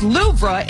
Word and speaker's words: Louvre: The weight loss drug is high on the list, along with Storm Eowyn and the Louvre Museum, which is pronounced Louvre in France.